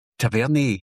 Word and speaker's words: Tavernier: Or people who could never pronounce Tavernier correctly. Tavernier.